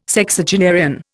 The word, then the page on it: Sexagenarian: Indian